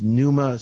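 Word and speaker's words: Pneuma: The name of the company is Numa Solutions, and it's spelled with a P. Why? Because it's fun that way. No, it's a P-N-E-U-M-A Solutions. And Numa is a Greek word that means wind with knowledge.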